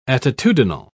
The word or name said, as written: attitudinal